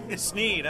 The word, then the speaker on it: Sneed: Sneed. S-N-E-E-D.